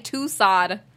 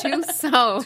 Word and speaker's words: Tussaud: I've been saying Tussaud. And it's Tussaud. – It's French, right? – I'm so embarrassed.